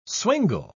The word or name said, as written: swingle